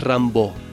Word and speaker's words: Rimbaud: Creo que en francés es Rimbaud, pero yo muchas veces lo he escuchado como Rimbaud, así que bueno, como les guste, se escribe Rimbaud en realidad.